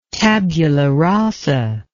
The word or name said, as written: tabula rasa